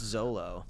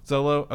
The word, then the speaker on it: Xolo: I cannot pronounce his name. It's XOLO. – I believe it's just Zolo. – Zolo.